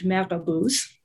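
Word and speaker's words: marabout: At the moment in the region, there are figures who are called in French marabouts, right, which is based on a kind of French colonial mispronunciation of the Arabic murabit, but they are people who engage in these practices.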